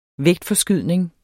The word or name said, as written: vægtforskydning